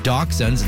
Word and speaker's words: dachshunds: It's a celebration of dachshunds. And yes, that's apparently how you pronounce it.